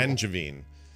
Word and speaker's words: Angevine: How do you say the name of the school? – In Lafayette. Oh, Angevine Middle School. – Angevine. – Engadine.